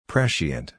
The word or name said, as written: prescient